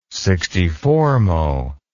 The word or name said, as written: sixty-fourmo